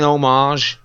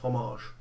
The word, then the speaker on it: homage: I think it's clearly an homage. – I think you'll find the word Tarantino loves. – You'll find the word is pronounced homage.